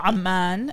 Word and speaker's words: Amman: Oh, Amman is how you pronounce it in Arabic.